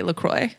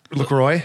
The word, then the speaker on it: LaCroix: I appreciate that you said it correctly. LaCroix. – Yeah. – LaCroix. – LaCroix. – That's how you say it.